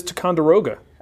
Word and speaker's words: Ticonderoga: That is Ticonderoga.